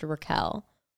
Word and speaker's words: Raquel: I heard she was always called Rachel and then for the show decided that her name would now be pronounced Raquel.